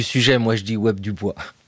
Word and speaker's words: Du Bois: son nom s'écrit Dubois, ça se prononce Dubois.